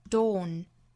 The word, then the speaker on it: dawn: The dawn will break，这里面的 dawn 的发音，我们注意英音之中啊，是一个比较紧凑的一个 o 的发音，o 这个元音读准了之后，再加前面后面的辅音，读成 dawn，一定不要把它和另外两个词混淆了，一个就是 d o w n down，还有另外一个呢就是 d o n e done。